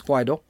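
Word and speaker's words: Guaidó: The people of Venezuela, headed by President-elect Juan Guaido, and that's how you do pronounce it, it's Guaido, started their quest for overthrowing the evil socialist dictator Nicolas Maduro.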